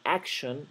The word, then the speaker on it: action: Así que fíjense que la C con la T en action no es acción, es action.